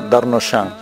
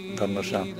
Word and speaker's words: Darnauchans: Eduardo Darnoyan, apellido, se escribe Darnauchans, Darnoyan.